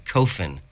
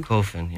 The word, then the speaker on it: Coffis: Well, actually, it's a Greek name, and you're saying it wrong. It's actually Coffin. So, yeah. – Coffin. – Coffin, yeah.